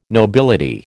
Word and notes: nobility: American English

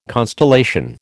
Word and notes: constellation: American English

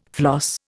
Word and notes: floss: Indian

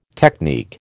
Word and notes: technique: American English

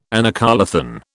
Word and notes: anacoluthon: British English

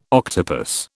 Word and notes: octopus: British English

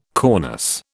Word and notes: cornice: British English

